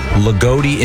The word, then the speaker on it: Loogootee: L-O-O-G-O-O-T-E-E. – Lagodi? – Lagodi, Indiana. – Lagodi, yeah. – Sure. – Wow, Lagodi. That's a great name. It sounds like a camp. – I wonder what they are. Lagodi Warriors or something? – Lagodi?